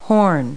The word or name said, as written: horn